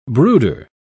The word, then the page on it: brooder: British English